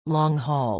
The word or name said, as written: long-haul